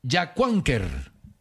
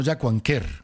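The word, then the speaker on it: Yacuanquer: La pronunciación del municipio de la semana pasada es Yacuánquer. No Yacuánquer.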